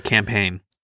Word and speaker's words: campaign: It was a campaign. – Okay, so C-A-M-P-A-I-G-N. – Yeah, I forgot the G. It's a silent G.